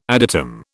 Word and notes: adytum: British English

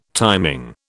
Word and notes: timing: British English

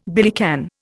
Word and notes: billycan: Indian

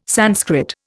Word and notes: Sanskrit: Indian

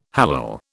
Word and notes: hallal: British English

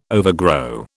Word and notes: overgrow: British English

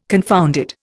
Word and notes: confounded: Indian

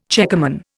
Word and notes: checkerman: Indian

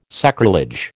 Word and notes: sacrilege: American English

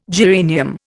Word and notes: geranium: Indian

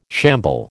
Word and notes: shamble: American English

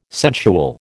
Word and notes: sensual: American English

